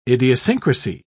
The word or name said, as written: idiosyncrasy